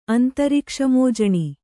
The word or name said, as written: ಅಂತರಿಕ್ಷಮೋಜಣಿ